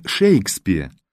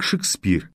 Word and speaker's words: Шекспир: Так, например, фамилия гениального английского драматурга по-настоящему, то есть по-английски, выговаривается как Шейкспир, а мы ее произносим и, вероятно, всегда будем произносить как Шекспир.